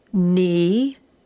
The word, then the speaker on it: knee: All right, starting with lesson 122, we're going to have the N sound spelt K-N, and of course the K is silent, so you can read these with me. The K is silent, will sound the letter N. Knee,